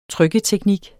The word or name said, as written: trykketeknik